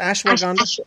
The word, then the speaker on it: ashwagandha: Frankincense, myrrh, ashwagandha. Yeah. Is that how you pronounce that? Ashwagandha? – Ashwagandha. – So ashwagandha.